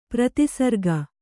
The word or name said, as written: ಪ್ರತಿಸರ್ಗ